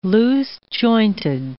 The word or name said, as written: loose-jointed